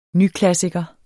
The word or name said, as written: nyklassiker